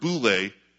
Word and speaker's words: boule: It's the word boule, B-O-U-L, long E, or boulamai, B-O-U-L-E-M-A-I.